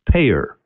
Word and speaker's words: PAEER: And actually, in the book, we've created a model, and the acronym for the model is PAEER, 5 letters, pronounced payer.